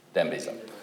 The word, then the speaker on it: Dambisa: Those who didn't hear, the bookends of the aid debate are the Bill Easterlys and, how do you pronounce her name from Zambia? – Dambisa Moya, who really says aid's pointless and government-to-government aid is just wasted, it's corrupt government.